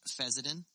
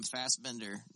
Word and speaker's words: Fessenden: I want to call him Fassbender no matter what, and it's not...